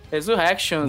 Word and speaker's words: Resurrections: Porque hoje estamos reunidos para falar de Matrix Resurrections. É assim? Falei certo? – Resurrections, muito é isso aí.